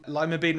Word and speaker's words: Lima: Okay, they are named after Lima in Peru, and although they're named after Lima in Peru, they're pronounced differently. This year, Lima Bean Respect Day will fall on a Monday, so take the day off if you can.